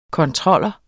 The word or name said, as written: kontroller